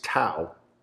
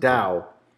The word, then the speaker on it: Tao: And Dow, which most people pronounce Tao. – Whoops. – Yeah. T-A-O, it's pronounced Dow in the Venetian.